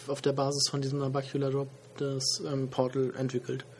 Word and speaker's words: Nabacular Drop: Nabacular Drop. Genau. Habe ich es richtig ausgesprochen? – Nabacular Drop, genau.